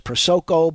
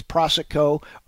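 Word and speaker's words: Prosoco: I've seen it pronounced as Prosico, but it's Prosico.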